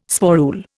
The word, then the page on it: sporule: Indian